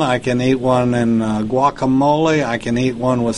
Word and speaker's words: guacamole: It's guacamole. Even the Mexicans say it that way.